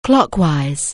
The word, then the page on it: clockwise: anglais